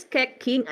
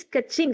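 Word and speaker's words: Keqing: I thought it's Kecking at first, then my brother told me. What do you mean Kecking? It's Kecching.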